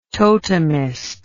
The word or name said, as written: totemist